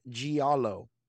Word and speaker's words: giallo: It looks like giallo. G-I-A-L-L-O. Giallo. It is an Italian word.